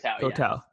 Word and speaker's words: Koh Tao: I'm just honestly, disclaimer, I'm just looking at Google pictures of, what is it, Koh Tao? Is that how you pronounce it? – Koh Tao.